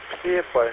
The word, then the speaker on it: Псебай: Мой поселок, мой китайский поселок Псебай. Псебай. Это юго-восток Краснодарского края. Кавказский город. Псебай, например.